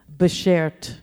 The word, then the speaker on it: Beshert: Like S-H-E-R-T at the end. Beshert.